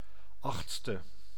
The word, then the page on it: eighth: /ˈeɪtθ/; [eɪtθ]; /ˈeɪθ/